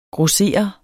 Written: GROSSERERES

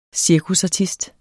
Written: CIRKUSARTISTEN

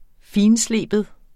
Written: finslibe

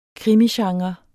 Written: krimigenre

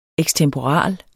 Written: ekstemporal

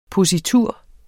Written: positur

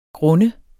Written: grund

